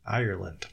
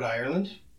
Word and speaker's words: Ireland: Ireland. – Ireland. Yeah, I wrote Ireland. – Yeah, you did. My tongue didn't say it right.